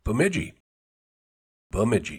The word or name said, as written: Bemidji